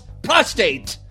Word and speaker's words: prostate: Oh, yeah, rub that prostrate. – First of all, it's prostate. Prostate. Prostrate is an entirely different thing.